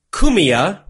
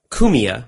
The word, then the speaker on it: Cumia: Anthony Cumia, I believe is how you pronounce it, is a radio host.